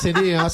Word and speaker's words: CNEA: Además, CNEA se lee por iniciales.